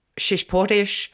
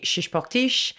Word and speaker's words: Chichiporchi: I'm happy for all accents to pronounce it, you know. So in Australian, it's Chichiporchi. In French, so it's actually French, so it would be Chichiporchi.